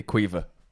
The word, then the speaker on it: Caoimhe: Oh, Niamh is spelled N-I-A-M-H, and Cuiva is C-A-O-I-M-H-E. – Of course it is. Quite clearly Cuiva.